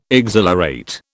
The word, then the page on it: exhilarate: British English